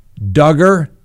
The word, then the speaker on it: Duggar: Now, Remember Josh Duggar? That's right. Rhymes with hugger.